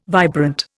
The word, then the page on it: vibrant: Indian